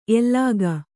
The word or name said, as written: ಎಲಗೇ